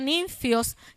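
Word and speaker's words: ninfios (νυμφίος): En las versiones modernas dice novio, no dice esposo, porque es una palabra tomada del griego que se pronuncia ninfios, que quiere decir novio.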